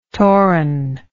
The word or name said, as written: toran